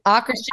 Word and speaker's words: Akershus: Akershus. – Akershus.